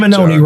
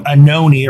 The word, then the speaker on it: Anoni: Last name Anoni rhymes with baloney. – Say it again. – Last name Anoni.